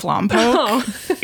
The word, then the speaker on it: Lompo': And the name Lompoc comes from a Chumash word, which I apologize for my pronunciation, but I believe it's just Lompo. L-O-M-P-O, okay. – Apostrophe, which means stagnant water. – Oh, interesting. – Right? So right away, we're getting into the general vibe of Lompoc, as a little bit of a forgotten backwater and kind of the redheaded stepchild of the Santa Barbara County cities.